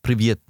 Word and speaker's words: Привет: Привет.